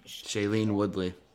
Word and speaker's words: Shailene Woodley: Shailene Woodley.